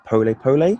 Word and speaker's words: pole pole: The title of your TED Talk, I believe, is pronounced Pole Pole.